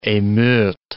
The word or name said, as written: emeutes